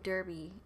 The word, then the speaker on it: Derbe: So they went to Lystra and Derbe instead. Hopefully I'm saying that right. D-E-R-B-E. – I'm going to say yes.